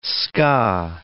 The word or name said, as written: ska